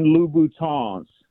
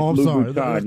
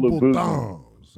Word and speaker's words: Louboutin: And then most importantly, So the women that listen to the show don't start tagging you on YouTube, they are Christian Louboutins. You got to pronounce it with the French Louboutin. You can't go Louboutins.